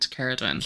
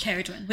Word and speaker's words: Ceridwen: Before this, right, I call it Nuala and, oh, yeah, and Ceridwen. However, it is pronounced Nuala. And Ceridwen. – Ceridwen, which in, you found out from the audiobook.